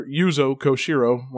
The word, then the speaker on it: Yuzo Koshiro: So we got two fantastic songs from Etrian Odyssey 4 and composer Yuzo Koshiro. Want to make sure we say it right at least once.